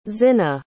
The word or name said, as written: zinna